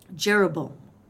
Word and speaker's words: Jeroboam: Jeroboam.